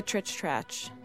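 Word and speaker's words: Tritsch-Tratsch: I think it's the trich-tratch.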